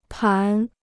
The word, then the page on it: 盘: pán